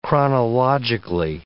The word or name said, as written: chronologically